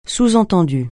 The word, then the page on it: sous-entendu: francais